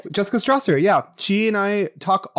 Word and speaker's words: Stawser: It's A Million Reasons Why, and it's by Jessica Stosser, S-T-A-W-S-E-R. She has... – Wait, say her name again. – Jessica Stosser, S-T-A-W-S-E-R.